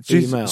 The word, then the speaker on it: Male: From the 90s, but it's spelt M-A-L-E, like a male. – Oh, right. – Email.